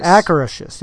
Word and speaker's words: Akershus: Will Anna and Elsa, of course, the princesses from Frozen, will they appear at, I can never say it right, it's an Epcot. Akershus. There you go.